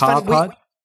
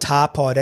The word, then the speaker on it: TaPod: It's TaPod. Funny. I think that when we came up with the name, we probably didn't think it through properly, because people call it TaPod, TaPod, but we call it TaPod, as in like a piece of tar baby.